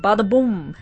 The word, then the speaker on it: Badaboum: Bada Boom, before that, I love to say it, Bada Boom, it's spelled B-A-D-A-B-O-U-M, and that song is A.E.